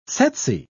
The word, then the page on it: tsetse: British English